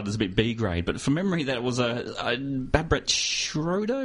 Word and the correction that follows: Barbet Schroeder: am I pronouncing that right, directed it? – Barbet Schroeder sounds about right.